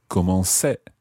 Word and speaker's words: commençais: You would spell it C-O-M-M-E-N-C with a little cedilla, A-I-S. – That's exactly right. So you put on the cedilla to keep the C sound soft. Otherwise it would be je commençais, and obviously that doesn't sound right. So je commençais, tu commençais, il commençait, elle commençait.